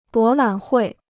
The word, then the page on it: 博览会: bó lǎn huì